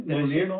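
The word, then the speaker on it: Mjölnir: Did I say that correctly? – Mjolnir? Yeah.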